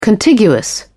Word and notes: contiguous: British English

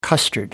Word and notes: custard: American English

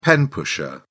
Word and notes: pen pusher: British English

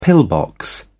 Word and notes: pillbox: British English

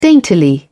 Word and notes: daintily: British English

